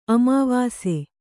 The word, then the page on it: ಅಮಾವಸೆ: amāvase